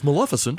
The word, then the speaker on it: Maleficent: It's Maleficent.